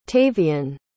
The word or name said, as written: tavian